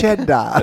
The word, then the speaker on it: cheddar: He said like, oh, is it skedda? It's actually pronounced cheddar.